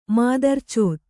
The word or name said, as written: ಮಾದರ್ಚೋತ್